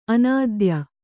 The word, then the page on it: ಅನಾಧೇಯ: anādhēya